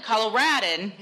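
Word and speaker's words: Coloradoan: According to the Fort Collins Coloradan. – Yeah. – All right. There's an O in there. – There is an O. – It's silent. – The O is silent.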